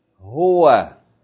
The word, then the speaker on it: ه: Okay, this Ha at the end of the alphabet is pronounced easily, like the ha in the word huwa.